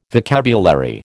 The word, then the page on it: vocabulary: American English